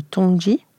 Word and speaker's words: Tongji: Depuis 2016, vous êtes directrice du double master franco-chinois à l'École nationale supérieure d'architecture de Versailles et l'université de Tongji, on, le prononce ainsi, à Shanghai.